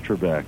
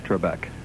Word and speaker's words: Trebek: Alex Trebek. – Trebek.